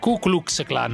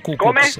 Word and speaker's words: Ku Klux Klan: Ku Klux Klan, non Ku Klux.